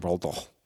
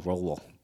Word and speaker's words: Roald Dahl: That's how you say his name, Roald Dahl. R-O-A-L-D space D-A-H-L, Roald Dahl.